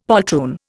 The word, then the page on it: poltroon: Indian